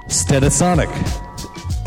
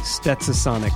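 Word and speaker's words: Stetsasonic: Can you pronounce it correctly, though? Stetasonic. – Stetasonic? Correct. Is there an S I'm missing? – Yes. Prince Paul, though. – The S is not silent.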